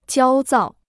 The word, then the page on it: 焦躁: jiāo zào